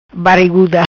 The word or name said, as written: Bariguda